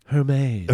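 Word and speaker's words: Hermès: Alyssa's chirping me for my pronunciation of... what was this store? Hermes? H-E-R-M-E? I don't know. do you know, it's like a luxury store. – I believe it's pronounced Hermes.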